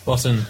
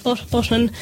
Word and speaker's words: Botton: Botton, yeah. – Botton.